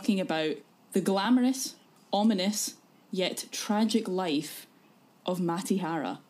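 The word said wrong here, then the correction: Mata Hari: Sorry, Matahari. Fuck off. I said it wrong.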